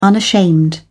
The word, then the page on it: unashamed: British English